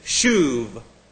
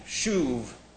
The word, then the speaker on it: shuv: The word most frequently employed to indicate man's repentance is shuv. And that would look like this, S-H-U-V. B, shuv, S-H-U-B. Only since that doesn't have a dot in there, it's pronounced like a V. Shuv.